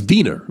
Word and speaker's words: Wiener: It's wiener because is pronounced with a V.